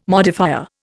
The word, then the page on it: modifier: Indian